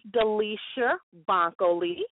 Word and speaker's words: Delisher Bankhole: So my name is Delisher Bankhole. Okay. And I am an entrepreneur from Chicago. That's okay. That's okay. I know it looks a little strange phonetically.